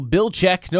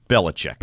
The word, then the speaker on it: Belichick: So he's two syllables. – Right. – The coach is three syllables. – Yes. – This is Belichick. – Yes. – Versus Belichick.